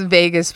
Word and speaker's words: vagus: This is Vegas spelled like V-A-G-U-S, not like Las Vegas.